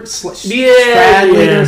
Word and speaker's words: Stradlater: Ackley is there's also his own roommate, Ackley's roommate, Stradler? – Stradlater. – Or something like that. I forget how it's pronounced. Stradlater, I think.